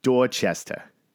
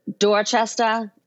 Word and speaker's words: Dorchester: I'm saying Dorchester, but that's not the correct pronunciation if you're from there, right? You got to say Dorchester. – Dorchester, and people who are from Dorchester are dot rats.